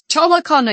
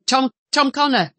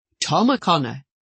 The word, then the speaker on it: O'Connor: Nobody liked you getting the name wrong because they would say, dear me, it's Tom O'Connor. You know, if I said Tom Connor, you know, Tom O'Connor, the O was very precise.